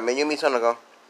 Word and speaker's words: Mayumi Tanaka: So what it says here is voice actress Mayumi Tanaka, I'm pronouncing it right, Mayumi Tanaka, addresses the question of who will voice Luffy after her.